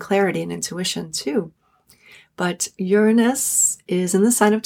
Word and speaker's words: Uranus: But the one I want to talk about here is the planet Uranus. That's how I pronounce it. I know there's many different pronunciations, or a few different pronunciations, but Uranus, the planet of liberation, of freedom, of revolution, of radical change, of shift of assembly point.